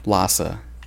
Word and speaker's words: Lhasa: Is that how you pronounce that? Yeah. – Yeah. – Lhasa.